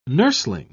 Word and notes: nursling: British English

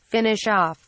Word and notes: finish off: British English

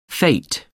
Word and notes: fete: American English

